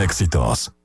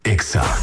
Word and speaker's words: Exa: No se dicen éxitos, se dice Exa. – En todas partes. – En todas partes. Ponte Exa FM.